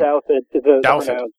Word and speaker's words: Douthat: I don't know how to pronounce his last name. – Ross Douthat. – Okay, Douthat. Do you know him at all? – Oh, yeah.